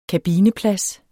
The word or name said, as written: kabineplads